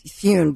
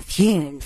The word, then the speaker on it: Thune: They're not, but remember we talked about this on Tuesday with Sean Farish, and I said at the time, I think it's Thune because, and we have to say his name, Thune.